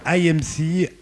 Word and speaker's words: Aymce: Il s'appelle IMC, A-Y-M-C-E.